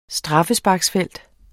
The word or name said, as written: straffesparksfelt